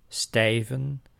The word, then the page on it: stijven: /stɛj.və:/